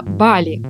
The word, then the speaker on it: Бали: Так, во-первых, действительно Бали, ударение на первый слог.